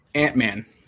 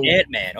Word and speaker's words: Antmann: And, you know, what's behind the handle name Antmann? Is that how you say it? Antmann? – Antmann. So Antmann.